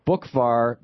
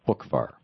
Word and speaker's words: Boockvar: But it's spelled BOOCKVAR, even though it's pronounced as if the C was not there. Buchvar.